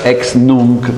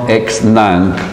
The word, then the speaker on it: ex nunc: Si pronuncia ex nunc, non ex nunc, come dicono alcuni speaker televisivi, perché capita anche questo in Italia.